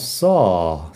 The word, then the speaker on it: saw: Luego tenemos saw. Saw es el pasado. Y fíjate en la pronunciación. Saw. Saw tiene como una A un poco abierta, pero más que nada larga.